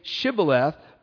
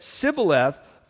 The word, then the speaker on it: Shibboleth: And if he said no, then they would say to them, 'This say Shibboleth,' but he said Sibboleth, for he could not pronounce it correctly.